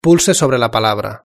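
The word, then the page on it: pulse sobre la palabra: European Spanish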